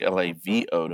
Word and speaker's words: LAWO: Sounded like LAVO to me, but that's fine. – Well, it's what you've always said, but I'm telling you, the Germans pronounce their W's like V's.